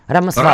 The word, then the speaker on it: Рамасвами: Я думаю, ударение все-таки, наверное, так. Или Рамасвами, или Рамасвами. – Рамасвами.